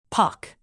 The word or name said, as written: pock